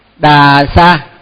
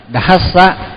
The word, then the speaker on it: dhassa: Thì mình đọc á là thường á là vì mình đọc quen á, rồi mình cứ đọc là đà sa, nhưng mà đà sa là không đúng, thì mình phải đọc là dhassa dhassa mới đúng.